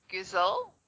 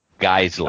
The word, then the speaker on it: Geissele: Gizel. – Geissele.